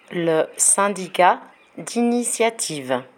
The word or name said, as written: le syndicat d'initiative